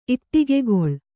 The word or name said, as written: ಇಟ್ಟಿಗೆಗೊಳ್